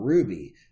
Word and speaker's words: RWBY: And also the voice of Blake Belladonna on Ruby, which is spelled RWBY.